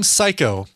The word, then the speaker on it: Sycco: But she goes by the name Psycho, and it's spelled S-Y-C-C-O.